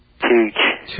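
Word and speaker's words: Tüch: Tooch. Is that right? – Tooch.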